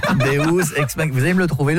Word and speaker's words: Deus ex machina: Deus ex machina. – Ah, parce que deux, j'aurais dit que c'était deux ex que t'appelles machine. – Non, ça se prononce Deus ex machina.